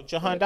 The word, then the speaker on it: Jahan: I got, how do you pronounce his first name? His last name, Dotson, from Penn State. – Oh, Jahan Dotson.